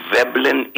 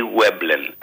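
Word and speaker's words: Veblen: Και λέγεται η Βέμπλεν ή Βέμπλεν, ανάλογα με το αν θα χρησιμοποιήσεις γερμανική προφορά ή αγγλική. Τέλος πάντων, το πούμε εμείς Βέμπλεν, τον άνθρωπο, ο οποίος κατηγοριοποίησε ορισμένα αγαθά, τα οποία δημιουργούν το εξής παράδοξο.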